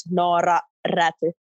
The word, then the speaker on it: Noora Räty: So you really need to roll, roll your R. So it's Noora Räty. So two R's there that kind of get the North American people.